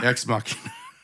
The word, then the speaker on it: Ex Machina: Ex Machina. Just how they say it in the movie.